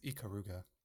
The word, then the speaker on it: Ikaruga: I mean, it's Ikaruga. – Ikaruga? Okay. – It's cool. Japanese, so you just pronounce it like that.